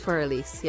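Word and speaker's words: Für Elise: Is that how you pronounce it? I thought it was fur ends. But no, I don't know. I am like, I don't know. I English is my second language. – I think it's for Elise. – Yeah. For Elise.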